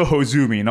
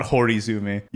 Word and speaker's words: Hozumi: Well, it's also Hozumi, not Horizumi. You keep adding syllables to things.